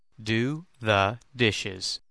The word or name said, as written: do the dishes